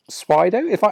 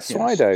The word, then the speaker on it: Swydo: tool called Swydo. Have I pronounced that right? – You pronounced it right. – Swydo. – Yes. Swydo.